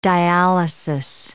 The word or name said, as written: dialysis